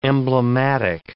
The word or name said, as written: emblematic